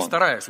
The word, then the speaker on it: постараюсь: Постараюсь. Ударение на слове постараюсь.